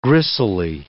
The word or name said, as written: gristly